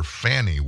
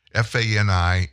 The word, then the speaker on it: Fani: I, I, think every other person in the news called her Fannie Willis. Well, she's corrected it. F A N I in her case is pronounced Fani Willis.